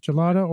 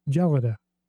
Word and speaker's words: gelada: It's apparently, I saw two pronunciations listed online, both with the soft G, as gelada or gelada.